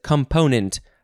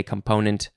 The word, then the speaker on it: component: Okay, next is component. First form component. I guess some people also emphasize the first syllable. Component? No, you got to say component. That's the only way that I'll allow.